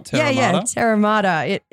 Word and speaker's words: Terra Mater: Terra Terra Mater.